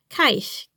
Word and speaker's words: Keish: Keish is pronounced Keish. K as in Keish, Keish, Keish.